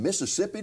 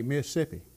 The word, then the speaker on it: Mississippi: And thanks for driving in all the way from Mississippi to be here. Yeah. Now, I didn't say that right, did I? – No, you didn't. You have to say Mississippi.